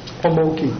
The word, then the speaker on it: Amoki: How do you say your last name? – Amoki. – Amoki. – And it's not Japanese, it's Hungarian.